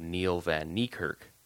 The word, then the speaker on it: Neil Van Niekerk: So the black foamy thing was I read about it on a guy's blog, a guy by the name of Neil Van Niekerk. I don't know if I'm pronouncing his name right. – That's how it's spelled. That's how I would pronounce it.